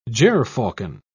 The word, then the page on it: gerfalcon: British English